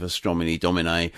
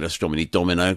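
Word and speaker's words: Astronomy Domine: This is especially for Neil, who pulled me up on my pronunciation of Astromini Domine last week when I said Astromini Domino, because I was thinking I'm having a pizza, maybe, I don't know, something along those lines.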